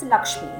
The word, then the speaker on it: LXME: And her most recent venture and passion is Lakshmi, which is spelled as LXME.